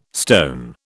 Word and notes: stone: British English

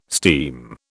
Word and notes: steam: British English

